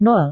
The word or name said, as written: nol